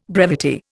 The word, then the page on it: brevity: Indian